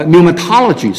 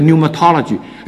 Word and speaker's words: pneumatology: 还有圣灵哈啊 pneumatology 圣灵论叫 pneumatology，前面这个 p 不发音啊，所以就是 n 出来的，这些都是从这一个字根出来的。